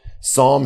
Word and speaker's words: Psalm: It's probably near the middle Psalm. If you're new to the Bible, Psalm starts with a silent P. It's tricky that way.